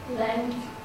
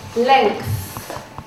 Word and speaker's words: length: L-E-N-G-H-T. – Is that correct? T-H, remember, when you say the word length, it's the T-H sound, okay, length.